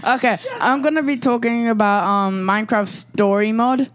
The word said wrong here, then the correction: Story Mode: Yeah, story mode.